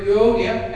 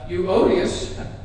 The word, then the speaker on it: Euodia: And they were Euodia and Syntyche. Oftentimes people jokingly mispronounce their names as Euodius and Syntyche.